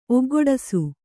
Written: ಒಗ್ಗೂಡಿಸು

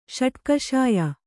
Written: ಷಟ್ಕಷಾಯ

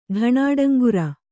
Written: ಢಾಣಾಡಂಗುರ

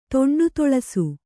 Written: ತೊಂಡುತೊಳಸು

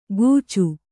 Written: ಗೂಚು

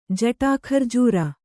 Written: ಜಟಾಖರ್ಜೂರ

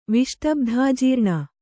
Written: ವಿಷ್ಟಬ್ಧಾಜೀರ್ಣ

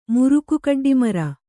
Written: ಮುರುಕುಕಡ್ಡಿಮರ

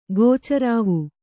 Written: ಗೋಚರಾವು

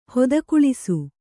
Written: ಹೊದಕುಳಿಸು

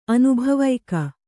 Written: ಅನುಭವಿಕ

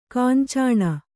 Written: ಕಾಂಚನಿ

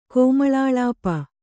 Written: ಕೋಮಳಾಳಾಪ

